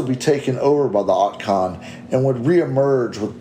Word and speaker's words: Otkon: So it's spelled O-T-K-O-N. So okay. Forgive me if I'm not pronouncing it right, but Otkon. – Yeah, sounds right to me.